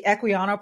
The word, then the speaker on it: Equiano: So let's start with the, am I saying this right? The Equiano Project? – You are, yes.